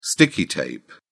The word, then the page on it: sticky tape: British English